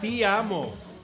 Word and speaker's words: ti amo: Ti amo, ¿no? – Te amo. – Ti amo. Ti, ti, ti.